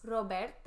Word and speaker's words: Roberta's: I can't roll my R's. – Roberta's.